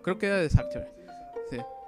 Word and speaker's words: Sartre: Mira, por ejemplo, hay una frase de Jean-Paul Sartre que dice, es Sartre, que según yo se pronunciaba como G, la R, bueno, Sartre, que dice la, dice, la libertad consiste en, en elegir, pues, qué hacer con lo que han hecho de nosotros.